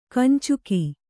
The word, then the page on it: ಕಂಚುಕ: Kannada